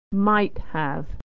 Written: might have

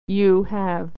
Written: you have

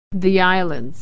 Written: the islands

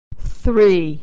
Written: three

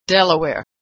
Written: Delaware

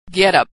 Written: getup

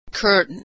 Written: curtain

